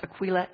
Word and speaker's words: Aquila: And yes, that is pronounced Aquila.